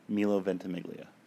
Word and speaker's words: Ventimiglia: Milo Ventimiglia. – How do you say it? – Milo Ventimiglia. – Ventimiglia. – Yeah, you added an extra syllable.